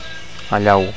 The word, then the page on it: allau: Catalan